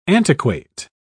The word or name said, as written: antiquate